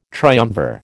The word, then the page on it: triumvir: American English